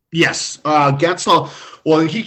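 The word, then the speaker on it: Guentzel: His teammate, Jake, was it Gwentzel, if I've pronounced that correctly? – Yep.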